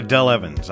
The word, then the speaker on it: Delavans: As we begin the Jeff Lorber Three of a Kind Showcase, I'm going to try this pronunciation on you. Delavans. It's supposed to Del Evans. I've been pronouncing it that way before.